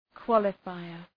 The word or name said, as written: qualifier